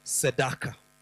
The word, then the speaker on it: tzedakah: I'll pronounce it and you know that word. If you know Swahili, you know that word. Because the word is sedaka. Sedak.